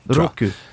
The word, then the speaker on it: Roku: Roku.